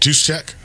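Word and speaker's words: Juszczyk: How do you say it? – Juice check. – Is that right, or are you just saying that? – I think that's right.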